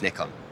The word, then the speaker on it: Nikon: Uh, in the US, uh, our US team definitely say Nikon, but in Europe, and in fact in Japan, where I believe the correct pronunciation must be more readily felt, it's Nikon.